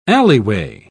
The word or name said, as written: alleyway